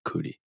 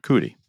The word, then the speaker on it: Coody: C-O-O-D-Y, Parker Cootie. – Cootie. That's how we're pronounced. We think it's those, Cootie? – Yeah, we, come on.